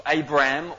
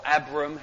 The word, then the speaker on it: Abram: Well, actually, chapter 11 introduces us to a man called Abram or Abram, however you want to pronounce it.